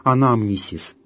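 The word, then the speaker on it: анамнисис (ἀνάμνησις): Далее следующая смысловая часть евхаристической молитвы – «анамнезис». Это греческое слово, но по-гречески, а не в латинизированном виде, оно произносится как «анамнисис».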